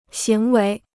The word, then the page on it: 行为: xíng wéi